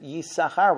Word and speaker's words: Yisachar: We have two sins, and we pronounce it Yisachar without the second sin.